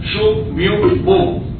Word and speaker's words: Sun Myung Moon: Sun se escribe S-U-N, Myung como suena pero con Y, Myung, y Moon con doble O.